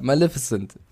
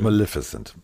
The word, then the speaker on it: Maleficent: Also ja, Maleficent. So spricht man es aus, bitteschön. Maleficent.